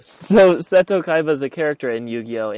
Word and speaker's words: Seto Kaiba: And this comes from Seto Kaiba, is that how you say his name? – Yeah, Seto Kaiba.